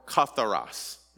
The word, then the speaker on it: katharos: So the word pure there is a word katharos, or, or katharos is, is the proper pronunciation.